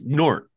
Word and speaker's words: Gnort: I think it's Gnort, like you swallow the G.